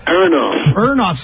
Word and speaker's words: Aronoff: I'm now going to refer to them as Kenny Erloff's Bombastic Meatbats. – Aronoff. – Sorry. – Aronoff.